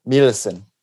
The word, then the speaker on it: Melissen: Melissen.